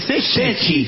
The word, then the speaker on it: sete: Se dice sechi. – Sechi. – Sí, claro, es de Italia usted. – Sete se escribe sete, pero se dice sechi.